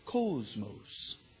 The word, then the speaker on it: cosmos: I got this cool little Greek pronunciation thing that I click on, and he says cosmos.